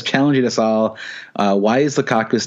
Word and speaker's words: Lukaku: Even though Lukaku rhymes with seasonal flu, Big Rom did not have the flu this weekend, and he banged in four goals.